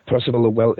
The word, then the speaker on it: Percival Lowell: I'm not sure if I pronounce Percival Lowell, yeah.